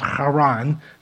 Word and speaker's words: Haran: It's actually pronounced Haran, something like that, but uh, we'll just stick with Haran.